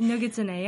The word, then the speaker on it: Nogitsune: Nogitsune.